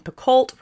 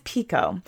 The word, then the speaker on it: Picoult: And Picoult was one of them. But it's Pico.